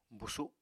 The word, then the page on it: busu: Apatani